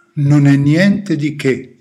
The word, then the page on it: non e niente di che: Italian